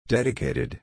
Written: dedicated

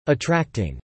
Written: attracting

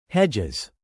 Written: hedges

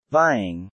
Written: vying

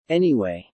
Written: anyway

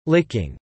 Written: licking

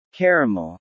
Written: Caramel

